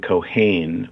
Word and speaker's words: Keohane: The alias was Kevin Cohane, which is spelled K-E-O-H-A-N-E.